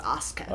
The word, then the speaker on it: Asuka: Asuka. It's pronounced Asuka.